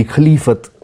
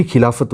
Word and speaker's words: Khilafat: So with that, this word Khilafat, many people say it should be Khalifat. It is actually Khilafat only.